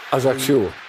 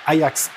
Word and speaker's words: Ajaccio: Wie spricht man es aus, Herr Reif? – Ajaccio. – Ajax. Ajaccio. Ajaccio.